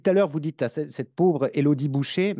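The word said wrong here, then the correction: Bouchez: mais euh, moi, j'ai toujours prononcé Elodie Bouchèze.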